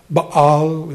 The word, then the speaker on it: Baal: You read about Baal and Baal is the way they pronounced it in the Old Testament.